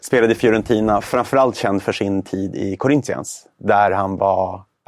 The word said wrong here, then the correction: Corinthians: det var ett riktigt dåligt uttal. Corinthians kanske? – Corinthians. Jag vet, jag vet, portugisiska, ja.